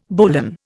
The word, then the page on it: bowline: Indian